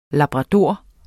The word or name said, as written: labrador